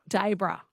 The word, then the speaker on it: Dayboro: Hi, or Dayboro. Yeah, I'm not sure how to say it. How do I say it? – Daybro.